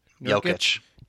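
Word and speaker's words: Jokić: Who's the tall dumbass Gurgic, or what did you say his name was? – Jokic. – Jokic? – Jokic. – No, well, Nurkic is on the Blazers. Jokic is on the Nuggets.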